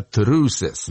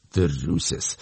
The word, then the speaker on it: Truces: Группа из Лондона, совсем новая, в 2019 году была создана, и называется она Truces. Но пишется это так, две буквы «Т», две буквы «Р», две буквы «Ю», ну и потом «С» и «С». Truces.